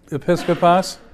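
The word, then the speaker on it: Episcopos (ἐπίσκοπος): Is it, how do you say it again? – Episcopos. – Episcopos. – Right.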